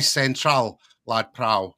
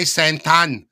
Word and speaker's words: Central Lad Prao: I recall one time I was in a taxi and I said to the taxi driver in the Thai language, but with British pronunciation, I said, "Bai Central, Lad Prao," which means I want to go to the shopping mall, Central Lad Prao. And the taxi driver looked behind and he was like, "What? What are you saying?" So I had to take this head off and put this head on, this Thai head, and go, "Bai Santan," in this kind of same pronunciation as the Thai people would say.